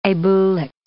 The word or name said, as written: abulic